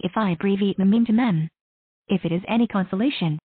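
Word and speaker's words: meme: It should be pronounced to rhyme with cream. Mem.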